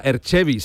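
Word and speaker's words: Erg Chebbi: Ajá, Erchevi se pronuncia, Manu.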